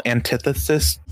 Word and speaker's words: antithesis: I mean, it's so weird, because he's, like, the moral antithesis. I did not pronounce that right, or my lips fucked that up. How do you pronounce that? Antithesis? – You said it right, but the lisp is great.